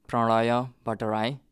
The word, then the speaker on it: Pranaya Bhatterai: My name is Pranaya Bhatterai, which is kind of simplified name in American version. The way it's pronounced in Nepalese is Pranaya Bhatterai.